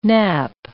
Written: nappe

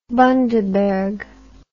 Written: bundaberg

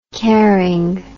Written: caring

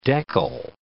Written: deckle

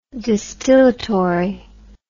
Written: distillatory

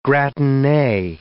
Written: gratine

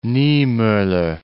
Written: Niemoller

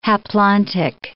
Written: haplontic

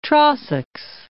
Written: Trossachs

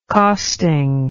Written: costing

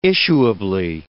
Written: issuably

